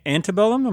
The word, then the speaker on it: Antebellum: 7, Antebellum. Am I pronouncing that right? – Yep.